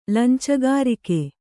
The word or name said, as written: ಲಂಚಗಾರಿಕೆ